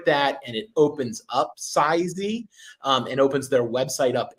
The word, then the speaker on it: Sizzy: It's either Sizey. That's how I say it. S-I-Z-Z-Y. Was that? – Sizzy, I think it's. – Sizzy? – Well, yeah, it's Sizzy. Spelled like Sizzy, but it's to show the sizes of your of yeah.